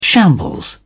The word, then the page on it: shambles: British English